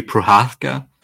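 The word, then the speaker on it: Procházka: Prochaska, is that how you pronounce his name? – Jiri Prohaska.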